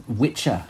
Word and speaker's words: Wicca: Wicca, Ages. – Yes. Yes. Yes. Which I think is pronounced Witcher originally.